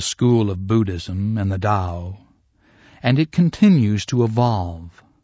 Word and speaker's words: Tao: That's spelled T-A-O, but pronounced Tao, D-A-O.